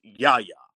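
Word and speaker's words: Yahya: The first name, I believe, is pronounced Yaya.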